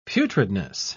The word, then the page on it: putridness: British English